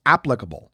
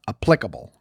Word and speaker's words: applicable: They're both 3 syllables, but it's the first syllable that you're supposed to emphasize. So it's applicable, not applicable.